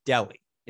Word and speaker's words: Delhi: It's the 777 and it's to Delhi. Am I saying that correctly? – Yeah.